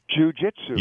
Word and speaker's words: jujitsu: Jujitsu. – Jujitsu.